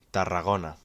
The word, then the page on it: Tarragona: Spanish